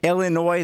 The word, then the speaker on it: Illinois: So you have Gonzaga, Baylor at number two, Illinois. The S is silent as always.